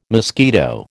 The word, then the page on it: mosquito: American English